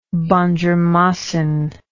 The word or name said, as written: bandjermasin